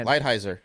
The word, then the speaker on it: Lighthizer: How do you say that? – Lighthizer.